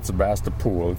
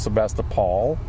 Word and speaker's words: Sebastopol: Well, Captain Ron, there's also no pool in Sebastopol. It's Sebastopol. It's not Sebastopol.